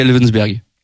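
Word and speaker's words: Elversberg: Si je le prononce bien, c'est Elvensberg. – Ouais, voilà.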